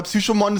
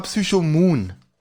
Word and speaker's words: Psychomoon: Also erstmal hat er es falsch ausgesprochen, weil Psychomon, das klingt wie ein Pokémon. Ja, ja, das stimmt. Das heißt aber Psychomoon.